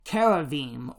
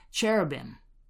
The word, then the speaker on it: cherubim: Now, of course, in Genesis chapter 3, that space gets separated, and at the boundary line of those spaces, God places a guardian called Keravim, or sometimes pronounced cherubim.